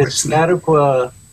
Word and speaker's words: Piscataqua: I want to mention, too, that you have recently been the recipient of the Piscataqua, I'll say it wrong, so to go ahead and say it, the grant that you just recently won. – Yes, the Piscataqua Artist Advancement Grant. – Thank you. That's it.